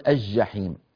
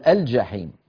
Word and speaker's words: الجحيم: في بعض يعني الإخوة ينطقونها شمسية فيقولون الجحيم الجحيم، وهي اللام هذه لام قمرية. يعني لا، ما معنى قمرية؟ يعني تنطق وتلفظ. هل نقول القمر أم نقول القمر؟ القمر فنلفظها. هنا أيضا نقول الجحيم الجحيم، ولا نقول الجحيم أو الجنة أو الجبل.